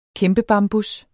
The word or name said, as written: kæmpebambus